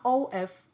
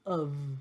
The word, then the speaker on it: of: 那 o f 这个字在念的时候都是念成 of of，就是前面 o 变成阳音，然后 f 是发成 v 的音哦，并不是气音，而是有震动的这个 v 的音。